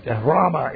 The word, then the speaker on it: rhema: As some people say rhema, but rhema is actually the correct pronunciation there.